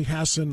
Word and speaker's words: Hassan: Am I saying it correctly, Hassan, not Hassan? – Yes.